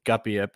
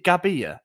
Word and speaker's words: Gabbia: So I think Chukwueze is a good shout, but I'm going to go with Gabbia because how are we meant to pronounce it? Isaac always kicks off about this. Is it Gabbia? – You're supposed to say both B's.